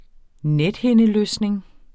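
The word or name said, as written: NETHINDELØSNING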